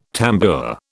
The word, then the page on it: tambour: British English